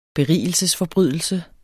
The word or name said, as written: BERIGELSESFORBRYDELSE